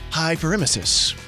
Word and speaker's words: hyperemesis: Hyperemesis? Hyperemesis. – Sounds right.